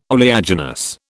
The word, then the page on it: oleaginous: British English